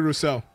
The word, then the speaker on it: Rousseau: Greg Roussard, I think his name, I always mispronounce his name. – Gregory Rousseau. – Rousseau, you know, thank you, fam.